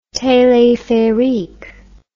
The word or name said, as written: telepherique